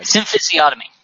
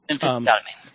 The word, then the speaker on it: symphysiotomy: There's a procedure, a medical procedure known as a I just had the actual pronunciation guide. – Symphysiotomy. – Thank you. – Symphysiotomy.